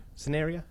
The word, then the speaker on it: cinerea: In relation to the Eucalyptus cinerea? Cinerea. Is that it? Okay, thank you. – I'm pretty sure that's it. – C-I-N-E-R-E-A. Cinerea, I think. Cinerea? – C-I-N-E-R-E-A.